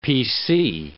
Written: PC

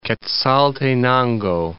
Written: Quezaltenango

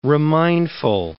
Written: remindful

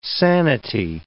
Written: sanity

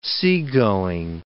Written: seagoing

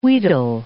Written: wheedle

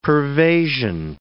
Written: pervasion

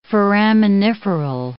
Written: foraminiferal

